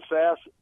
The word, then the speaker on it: Sasse: Pronounce it Sass. It's Sass. – Spelled S-A-S-S-E. It looks like it could be sassy, but yeah. It's pronounced Sass.